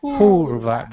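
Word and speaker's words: pūrva: Purva, that's a long U.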